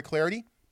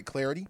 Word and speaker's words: Clardy: You can follow me on Twitter at Troy Clarity, at Troy Clarity. Last name is spelled C-L-A-R-D-Y.